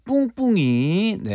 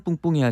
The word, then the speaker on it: 뿡뿡이: 꿍꿍이가 아니라 뿡뿡이인데요. 제가 발음이 좀 부족했나 봅니다.